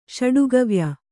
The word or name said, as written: ಷಡುಗವ್ಯ